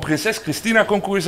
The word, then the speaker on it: Prinses Christina Concours: We hebben Alexander Buskemolen, welkom van Prinses Christina Concours, zeg ik dat goed? – Zeg je helemaal goed.